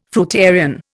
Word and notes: fruitarian: Indian

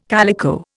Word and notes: calico: Indian

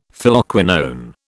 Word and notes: Phylloquinone: British English

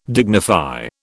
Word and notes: dignify: British English

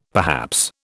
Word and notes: perhaps: British English

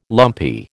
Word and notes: lumpy: American English

